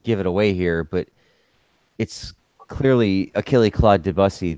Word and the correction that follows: Debussy: the French composer. – Debussy, excuse me, as you know, one of the first impressionist composers. – Say it right, Frenchie.